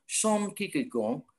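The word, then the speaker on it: Søren Kierkegaard: Nah, kalau menurut Soren Kierkegaard tulisannya gitu, tapi bacanya Son Kierkegaard.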